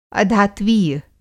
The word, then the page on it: अधात्वीय: Hindi